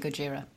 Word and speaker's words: Gojira: I don't know, because bear in mind we're pronouncing it wrong. – Gojira. – That's true, actually. Yeah, it should be Gojira.